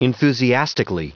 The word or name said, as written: enthusiastically